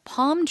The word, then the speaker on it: palm: P-A-L-M인데 발음할 때 palm tree 발음 다시 한 번요. – Palm tree. 약간 길게. L 발음이 나지 않는다고 생각하죠. 그렇죠. 그냥 길게 palm tree.